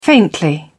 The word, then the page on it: faintly: British English